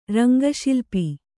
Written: ರಂಗಶಿಲ್ಪಿ

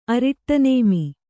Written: ಅರಿಟ್ಟನೇಮಿ

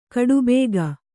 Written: ಖಟಬಾಕಿ

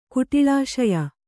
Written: ಕುಟಿಳಾಶಯ

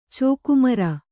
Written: ಚೋಕುಮರ

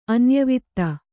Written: ಅನ್ಯವಧು

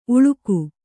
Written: ಊಳಿಗಿ

